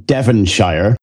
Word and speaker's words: Devonshire: In Devonshire. – Shire or Shire? – No, we say Shire, Devonshire. – That's what I thought. – Yeah. It's spelt Shire. So we're just being lazy, to be honest.